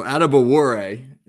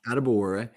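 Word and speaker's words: Adebawore: Are we even close with the pronunciation? – So Adebuwore, not bad at all. Adebuwore. Well, he's an interesting guy.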